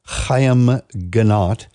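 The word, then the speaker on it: Haim Ginott: And no, that was not some static on your radio. That is the correct pronunciation of the gentleman's name, Chaim Ginat.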